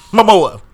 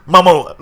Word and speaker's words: Momoa: Momoa. That's how you got to say his name, Tiff. – Yeah. – Momoa.